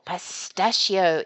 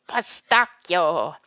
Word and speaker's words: pistacchio: For example, pistachio is pistacchio, mascarpone, mascarpone, and bruschetta, not bruschetta.